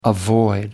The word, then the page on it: avoid: American English